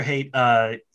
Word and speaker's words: Haidt: It's pronounced, by the way, it's pronounced Haidt, but it's spelled Haidt, H-A-I-D-T. That's how he pronounces it. So I'm pronouncing it the way he pronounces it.